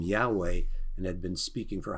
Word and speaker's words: Yahweh: Sometimes people want to pronounce it Jehovah, but that is not as accurate, actually, from all the studies that reveal Yahweh is the most accurate word for the name of God.